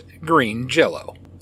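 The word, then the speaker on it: Green Jellÿ: Originally named Green Jello, spelled J-E-L-L-O, the band changed its name due to legal pressure from Kraft Foods, Inc., but I can assure you, it is still pronounced Green Jello.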